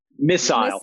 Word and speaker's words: missile: I hope I get bonus for the British pronunciation of missile. – I know. Good job. You made me say it that way. – Say it again. – Missile.